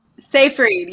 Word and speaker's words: Seyfried: Seyfried, yeah, Amanda Seyfried.